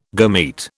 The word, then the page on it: gamete: British English